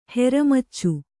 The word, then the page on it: ಹೆರಮಚ್ಚು: Kannada